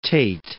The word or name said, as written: Tate